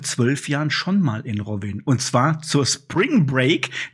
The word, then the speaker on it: Rovinj: Also das wird Rovinj geschrieben, aber alle Kroaten schwören drauf, das wird Rovinj ausgesprochen und ich glaube, ich habe es die letzten 10 Jahre falsch ausgesprochen, weil ich habe immer Rovinj gesagt oder Rovinj, aber eigentlich heißt das Rovinj.